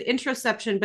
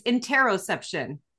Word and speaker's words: interoception: I said interoception, but it's interoception.